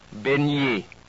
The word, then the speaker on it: baigner: Next, the letters G and N are pronounced somewhat like our NY in canyon or NI in senior. Listen to these. Montagne. Baigner.